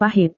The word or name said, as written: pahit